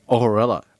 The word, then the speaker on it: Ohorella: Ohorella. – Ohorella. – O-H-O-R-E-L-L-A.